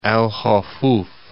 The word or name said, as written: Al Hufuf